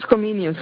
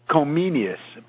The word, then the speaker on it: Comenius: Comensis? – John Amos Comenius. – Comenius.